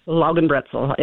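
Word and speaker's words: Laugenbrezel: What is Lagenbrezel? Is that, am I pronouncing that one right? – Lagenbrezel. It's a soft pretzel.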